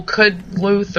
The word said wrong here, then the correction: Kudlitz: Kudlitz, that's it.